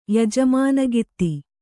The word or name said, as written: ಯಜಮಾನಗಿತ್ತಿ